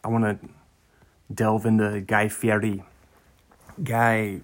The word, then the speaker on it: Guy Fieri: He pronounced it correctly. He's like, no, it's Guy Fieri.